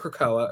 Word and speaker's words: Krakoa: The premise is, because you mentioned Krakoa earlier, I hope I'm saying it right, but. – That's how I've always said it, yeah.